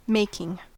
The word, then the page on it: making: American English